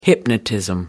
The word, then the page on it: hypnotism: British English